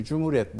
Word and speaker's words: cumhuriyet: Şimdi cumhuriyet sözcüğü, bakın şimdi cum aslında H harfli ince okunuyor. Hü uzatıcı. Hü diye ü ile. Cumhuriyet. Orijinali bu.